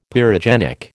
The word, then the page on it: pyrogenic: American English